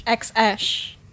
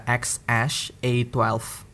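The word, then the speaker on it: X Æ A-12: nah, jadi um, salah satu yang kat, huruf yang paling sering dibaca adalah yang A sama E-nya gabung dan itu cara bacanya adalah ash. – X ash.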